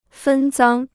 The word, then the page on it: 分赃: fēn zāng